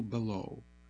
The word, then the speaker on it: Belleau: Michael Belleau, that's spelled B-E-L-L-E-A-U, Greenfield, Mass., Michael E.